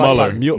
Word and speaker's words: Mueller: Mueller.